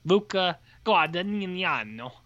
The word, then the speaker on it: Luca Guadagnino: Luca Guadagnino. That's a correct pronunciation.